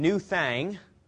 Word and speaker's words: Nu Thang: And New Thang, actual pronunciation, N-U-T-H-A-N-G, in 1990.